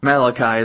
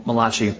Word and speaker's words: Malachi: So my dad said before, we'll be studying out of Malachi today, or Malachi is the proper pronunciation, but it sounds Italian if you say it Malachi.